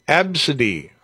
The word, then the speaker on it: ABCDE: The name ABCDE. Mm-hmm. Pronounced Absidy.